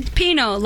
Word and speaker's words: Pinot: Pinot, like Pinot. The wine.